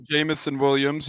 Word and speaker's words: Jameson Williams: Jameson Williams. Do I have that name right? Am I screwing up the name? I don't know. Did I get the name right? – No, you're right. Jameson Williams.